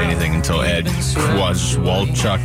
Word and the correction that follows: Kowalczyk: It is Ed Kowalczyk.